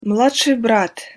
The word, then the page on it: mladsij brat: Russian